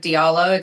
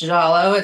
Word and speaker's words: Diallo: And sometimes I pronounce it phonetically like Diallo. It's really pronounced Jallo. It's a Fulani last name.